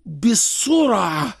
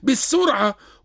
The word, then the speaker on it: الصرع: البخاري ومسلم متفق عليه: ليس الشديد بالصرع، هكذا نطقها صحيح. – نعم. – الصرع بالصاد. بعض الناس يعني العوام يقول بالسرعة، ونحن من ضمن أولوياتنا في برامجنا أن نصوب للناس لسان العربية فيهم.